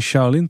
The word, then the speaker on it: Shaolin: Ehm, we zijn uiteindelijk ook in Shaolin terechtgekomen. Dus ik weet niet of het echt zo heet. Spreek ik nog enigszins goed uit? Dat spreek je heel goed uit, oh.